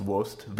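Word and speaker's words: Oast: It's spelled O A S T. I believe it's pronounced Wost.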